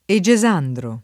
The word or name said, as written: Egesandro